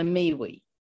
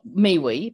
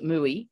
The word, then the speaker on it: miwi: So we have what we call a miwi. It's pronounced what miwi, but it's mm-hmm. uh, spelt M I W I miwi, or one elder calls it mui,